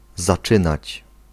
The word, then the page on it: aborder: France; [a.bɔʁ.de]